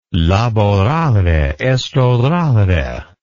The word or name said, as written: laborare est orare